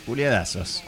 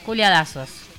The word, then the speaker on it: Culiadaz#s: ¿Culiadases? ¿Culiadats? ¿Cómo lo pronunciamos? – Culiadasos. – Culiadasos.